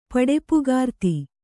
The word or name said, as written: ಪಡೆಪುಗಾರ್ತಿ